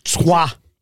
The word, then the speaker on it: Troyes: Trois. – It's a good logic. – Which is spelled Troyes. – How do you say N-A-N-T-E-S? – Jimmy Savile.